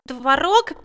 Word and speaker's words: творог: А вот в слове «творог» Мел Чернилыч, я запутался. Я слышал, как одни говорят «творог», а другие «творог». Как правильно? – Правильно делать ударение в этом слове на второй гласный. Творог.